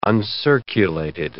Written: uncirculated

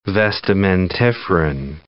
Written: vestimentiferan

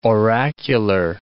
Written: oracular

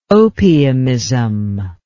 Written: opiumism

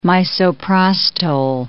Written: misoprostol

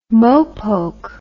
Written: mopoke

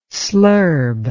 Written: slurb

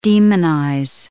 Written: demonize

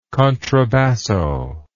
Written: contrabasso

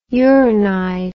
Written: uranide

